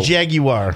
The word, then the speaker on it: Jaguar: The British say Jaguar.